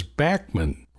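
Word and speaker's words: Bachman: And for the record, the way he pronounces his name is Backman.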